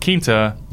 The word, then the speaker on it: Quinta: when I had them on, he was telling me it's pronounced Quinta, like La Quinta, the hotel.